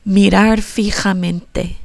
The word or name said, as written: mirar fijamente